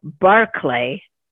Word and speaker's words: Barclay: Both of these books are very well written, and it's Linwood Barclay, or Barclay, depending on how you pronounce it, but it's B-A-R-C-L-A-Y, Barclay.